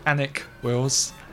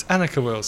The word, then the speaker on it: Annick Wills: Yeah, and I've been pronouncing this wrong apparently for about 35 years, and it's not Annick Wills. – It's Annika. – It's Annika Wills. So there we go. – Slightly better than Anika, which is another bad pronunciation of it that people do.